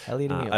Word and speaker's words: Heliot Emil: You can get into fashion in a lot of different ways, and I mean, with Heliot Emil, is that how you pronounce it? Emil. – Heliot, yes. Heliot Emil. – I didn't know if it was Emil, because in Sweden you say Emil for the name.